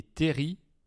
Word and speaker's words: terril: Euh, c'est un territoire singulier avec des petites euh, montagnes, des terris comme ils disent dans le Nord, parce que ça s'écrit bien Terril avec un L à la fin.